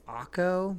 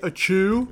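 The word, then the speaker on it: Acho: It's A-C-H-O. I'm going to say it's Akko, but Emmanuel Akko. Maybe something. – The white person would say a chew. Yeah. That's silly.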